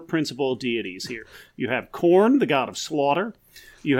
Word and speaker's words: Khorne: Nope, it's spelled K-H-O-R-N-E. – Oh, but it still sounds like corn, like maize. – I did not mispronounce it. I pronounced it 100% correctly. It sounds exactly like the food and or band.